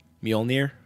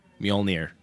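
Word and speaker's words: Mjölnir: Mjolnir. – Okay, say it again. – Mjolnir.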